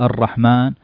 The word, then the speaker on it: الرحمن: ثم قراءة الكلمات، يقرأ الطالب أولا كلمة الله، ثم وضع ضابط الله أصلها بعد اللام ألف، الله، إله، إله في الكتابة ألف، الرحمن في الأصل الرحمن، لكن العرب اختصرت حذفت الألفات هذه لكثرة تكرارها، الله، بسم الله، الرحمن، ما يضعون ألف لكن تنطق ألف، الرحمن، لكن تكتب بدون ألف اختصارا.